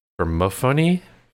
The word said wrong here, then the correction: Persephone: And for someone in broadcasting, my ability to pronounce things on my first try is terrible. Persephone.